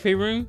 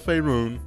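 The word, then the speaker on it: Faerûn: Is it Faerun? – Yeah, because like you said, it has the... – I was right. – You know, I don't even know why I try to do pronunciations and stuff like that. Try that like I'm right, so... I said Faerun. It's Faerun, Renee.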